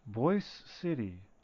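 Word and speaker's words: Boise: But it's pronounced the Z sound, not an S, and I don't think it's ever been called Boyce City.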